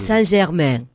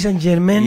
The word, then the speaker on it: Germain: Brutal. Paris Saint-Germain. – Exacto. Pronuncie bien, querido. – Germain.